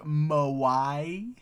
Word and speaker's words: moe: Like moai heads? That's my only frame of reference for anything that sounds vaguely like that. – No, it's spelled M-O-E. – Okay. – So Mo, but Mo-A.